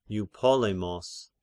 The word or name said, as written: Eupolemos